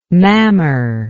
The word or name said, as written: mammer